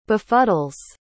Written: befuddles

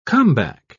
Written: comeback

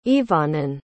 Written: ibhanan

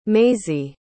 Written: maisey

